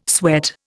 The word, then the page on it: sweat: Indian